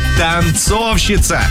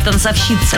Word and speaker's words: танцовщица: Танцовщица. О, как я танцую! Вот, на О ударение.